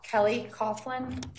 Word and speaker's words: Kelly Coughlin: Kelly Coughlin, K-E-L-L-Y. Coughlin is C-O-U-G-H-L-I-N.